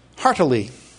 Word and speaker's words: heartily: Heartily. Three syllables. I want to say two. Heartily. But it's heartily.